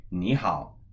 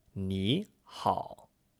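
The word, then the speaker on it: ni hao (你好): But when we say ni hao, it's pronounced like a second tone and a third tone. Ni hao, rising and then low and flat. – And it's not pronounced ni hao as two third tones, which would be two low and flat tones.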